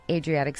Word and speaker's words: Adriatic: It's built on more than 100 small islands and a lagoon in the Adriatic Sea, which I probably mispronounced. – No, you did good.